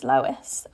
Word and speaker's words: Lois: But yeah, my name is Lois, and that's how you say it, but people get like really confused.